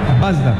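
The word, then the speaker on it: Bazdar: Samed Basdar. Ellos tienen el acento en la A.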